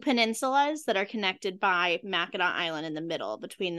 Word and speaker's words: Mackinac: So it's spelled like Mackinac Island, but it's pronounced Mackinac.